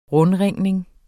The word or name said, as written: rundringning